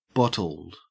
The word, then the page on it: bottled: Australian English